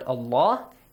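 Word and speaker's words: الله: The first one is about the pronunciation of the word Allah. It's pronounced heavily if there is a fatha or a dhamma sound right before. إِنَّ has a fatha, Allah, so there is a fatha before Allah, pronounce it heavily.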